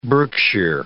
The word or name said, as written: Berkshire Hills